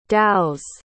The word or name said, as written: dhows